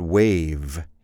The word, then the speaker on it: wave: Don't forget the V there, by the way. When you pronounce the word wave, you need to pronounce the V. How do you spell wave, by the way? W-A-V-E.